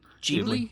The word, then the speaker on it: Ghibli: Ghibli? – Yep.